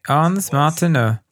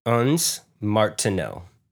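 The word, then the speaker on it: ondes Martenot: Ons Martineau. That's what it is. I've got it. – Ons Martineau. – You have to speak through your nose. Ons Martineau.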